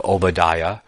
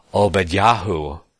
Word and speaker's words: Obadiah: We see that the name of God appended on the many end of many of the prophets' names, like Obadiah, or more accurately pronounced Obadyahu, and Obadyahu, right?